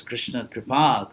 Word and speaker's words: Krishna Kripa: Yes, Krishna Kripa, Krishna's mercy. – The only person who pronounces that right, Maharaj.